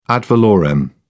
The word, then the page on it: ad valorem: British English